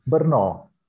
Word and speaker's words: Brno: É, a outra é que eu eu nunca, sei se a pronúncia é certa. É Brno, é isso? – Brno. – Brno, Brno. É.